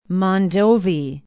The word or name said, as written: mondovi